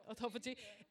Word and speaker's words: autophagy: And if you are able to fast, what your body does is goes into a state of autophagy, however you want to pronounce it. Yeah, autophagy. A-U-T-O-P-H-A-G-Y.